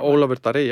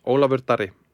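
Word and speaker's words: Ólafur Darri: Did I get the pronunciation about Olafur Darri, yeah. – Sorry? – Olafur Darri. – Okay, I didn't get it right.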